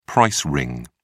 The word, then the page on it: price ring: British English